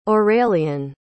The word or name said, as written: aurélien